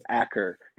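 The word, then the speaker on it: Achor: Sean Eichor. No, Sean. – Yes. Yeah. – That's the same. – Sean. I'm going to mispronounce it. Acker. It's A-C-H-O. – Sean Acker. Sean Acker. – Acker. There you go.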